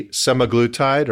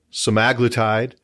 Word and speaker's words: semaglutide: And the generic name of this substance is, and there's different, you and I were joking earlier about how you pronounce this, like tomato or tomato. So it could be semaglutide or semaglutide. Same thing either way.